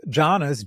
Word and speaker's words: jhana: So there's jhanas, J-H-A-N-A, or if you're being Sanskrit about it, dhyana, D-Y-A-N-A. And so jhana is the Pali. And then there's jnanas, which is N with a tilde, or N-Y. A-N-A, so which are knowledges.